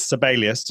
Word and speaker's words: Sibelius: I was doing some training for the correct pronunciation is Sibelius, just in case you were interested in that. He's a Finnish composer from Finland.